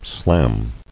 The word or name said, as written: Slam